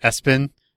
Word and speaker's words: ESPN: They generally pronounce it Espen, But yeah, that's a thing.